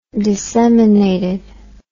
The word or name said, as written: disseminated